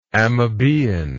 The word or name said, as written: amoebaean